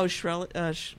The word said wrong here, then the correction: Shkreli: What's his name? – Martin Shkreli.